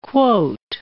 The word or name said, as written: quote